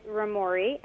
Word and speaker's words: Roamori: And tell me what the name Roa Mora means. – Yep. So the name of the company is Romori, and it's spelled R-O-A-M-O-R-I. And it's a combination of two different ideas.